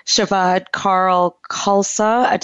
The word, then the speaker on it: Shabad Kaur Khalsa: And my guest today is Shabad Kaur Khalsa. Did I say that right? – Shabad Kaur Khalsa. Mm-hmm.